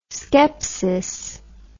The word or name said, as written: scepsis